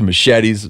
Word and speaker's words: machete: I mean, they got everything from hatchets to machetes. Brian Callen, who's here. How do you say that in Spanish? – Pronounced machete.